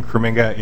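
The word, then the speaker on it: Krominga: My name is Ryan Kraminga. It's K-R-O-M-I-N-G-A.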